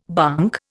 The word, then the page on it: bonk: Indian